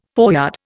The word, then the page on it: foreyard: Indian